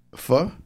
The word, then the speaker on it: pho: Pho? – Pho. That's what it's pronounced.